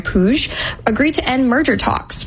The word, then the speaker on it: Puig: Oh, so that's how you pronounce it, Puig.